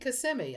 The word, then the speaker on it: Kissimmee: It is indeed Kissimmee, another Native American name.